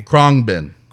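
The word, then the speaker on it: Khruangbin: Say it again for me. – Krongbin. – Krongbin. – Krongbin. – Krongbin. – I want to officially let everyone know that this is how you say this name, because...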